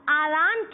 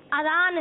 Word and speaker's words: اذان: بعض لوگ اذان کہتے ہیں، یہ غلط تلفظ ہے، بلکہ درست تلفظ اذان ہے۔